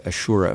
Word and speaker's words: Ashura: You build the book in part around a religious procession called the Ashura, if I'm pronouncing that correctly. – Yes, exactly.